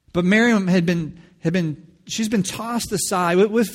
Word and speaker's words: Miriam: With few exceptions, Miriam, which is more accurate pronunciation of Mary.